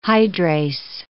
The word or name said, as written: hydrase